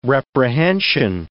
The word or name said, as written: reprehension